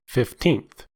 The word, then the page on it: fifteenth: American English